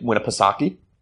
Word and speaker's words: Winnipesaukee: We went up, so we flew up the seacoast up to Lake Winnipesaukee. – Nice pronunciation.